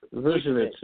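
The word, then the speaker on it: Vučević: And I'm really excited to see what Gordon and Vucevic, I think I said his name right. – Vucevic, yeah. – It's like Doncic.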